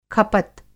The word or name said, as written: खपत